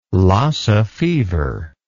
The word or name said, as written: Lassa fever